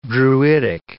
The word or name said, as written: druidic